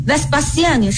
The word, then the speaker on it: Vespasienne: 大家听我念 Vespasienne 是不是跟我们这个韦斯巴乡这个发音是一样的？